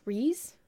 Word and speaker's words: Rhys: R H Y S Reese. Fionn Reese.